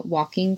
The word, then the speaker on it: waulking: I thought it was really cool that they took time for the walking group. It's spelled W-A-U-L-K-I-N-G, walking,